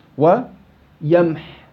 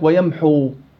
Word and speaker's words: ويمح: ويمح ويمح. بعض الطلاب يجي يقول لك ويمحو، لا ما في واو، توقف على حاء ساكنة.